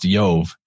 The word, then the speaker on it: Jove: So an I in the Roman version, Yove would actually be written J-O-V-E or I-O-V-E and pronounced Yove either way.